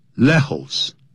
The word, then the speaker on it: Lejos: J 你要念成不能念成 J 也念成 H 的音所以呢 Lejos L-E-J-O-S 这是西班牙文不能念成 Lejos